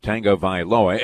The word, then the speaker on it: Tagovailoa: Let me get that right. You don't want to put that extra syllable in there. Ian Eagle corrected us on that. Tagovailoa.